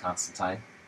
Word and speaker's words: Constantine: Which, during the series, he says that his name is pronounced Constantine.